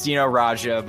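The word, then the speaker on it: Dino Rađa: Dino Rada. – You butchered his name. It's Dino Raja, but yes, that's correct.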